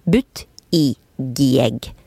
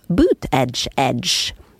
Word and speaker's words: Buttigieg: For hvem klarer vel egentlig å uttale navnet hvis de ser det skrevet? but i gieg. Men det er altså boot edge edge, som i støvel, kant, kant på engelsk.